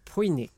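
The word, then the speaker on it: Pyunik: Pujnik. I think it's pronounced. – Perfect.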